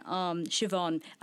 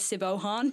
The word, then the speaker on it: Siobhan: Presently I just received a grant from Ollie, and I were talking about, my partner, on the way here about how I cannot pronounce this name, and it's really embarrassing. Siobhan, I keep reading it as Sibohan.